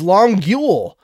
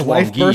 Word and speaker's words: Longueuil: But to my very Anglo brain, I pronounced it how it was spelled, which is Longueuil. – And I thought it was Longueuil, burst out to laughing.